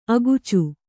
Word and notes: ಅಕಚ: akaca

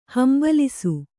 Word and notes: ಹಂಬಲಿಸು: hambalisu

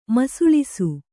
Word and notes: ಮಸುಳಿಸು: masuḷisu

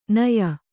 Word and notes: ನಯ: naya

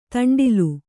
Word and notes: ದಂಡ್ಲೆ: Kannada

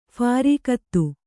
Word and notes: ಫಾರೀಕತ್ತು: phārīkattu

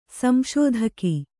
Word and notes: ಸಂಶೋಧಕ: sm'śodhaka

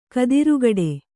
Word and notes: ಖುದ್ರಗೇಡಿ: kannada

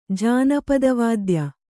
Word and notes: ಜಾನಪದವಾದ್ಯ: kannada

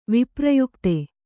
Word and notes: ವಿಪ್ರಯುಕ್ತೆ: Kannada

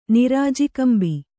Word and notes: ನಿರಾಜಿಕಂಬಿ: nirāji kambi

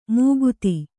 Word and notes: ಮುಕುತಿ: kannada

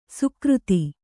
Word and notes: ಸುಕ್ರುತ: sukruta

